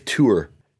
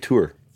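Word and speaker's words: Theurer: His name is pronounced Dave Tour. Is it Thur? Dave Tour.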